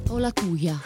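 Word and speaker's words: Olatuja: un grande talento per Alicia Olatuya o Olatuya, come la pronunci tu? – Olatuya.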